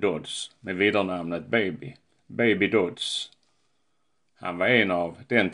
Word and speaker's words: Dodds: Namnet stavas Dodds, men uttalas Dodds.